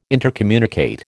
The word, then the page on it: intercommunicate: American English